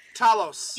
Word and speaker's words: Talos: Whatever, sorry, the pronunciation of this alien name is like wrong. – Talos. – You know what? He pronounced it Thalos. No, because Talos. he talks like that. He has a lisp, so he would say Thalos.